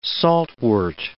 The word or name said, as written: saltwort